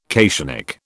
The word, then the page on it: cationic: British English